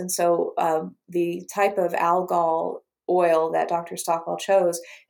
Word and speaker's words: algal: It's algal oil, actually, is what how it's pronounced.